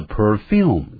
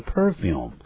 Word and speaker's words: perfume: The perfume, 但也有人念成 perfume, 就是香水啊，两个发音都可以。